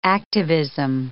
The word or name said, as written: activism